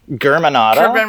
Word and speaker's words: Germanotta: Germanotta.